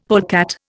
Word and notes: polecat: Indian

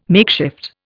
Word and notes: makeshift: Indian

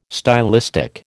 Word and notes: stylistic: American English

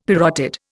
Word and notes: parotid: Indian

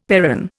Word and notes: perron: Indian

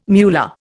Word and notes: muller: Indian

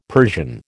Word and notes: Persian: American English

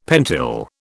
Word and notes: pentyl: British English

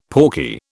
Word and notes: pawky: British English

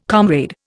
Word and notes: comrade: Indian

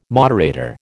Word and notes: moderator: American English

